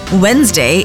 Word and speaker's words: Wednesday: Wednesday is pronounced Wednesday instead of Wednesday.